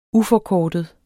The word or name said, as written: UFORKORTEDE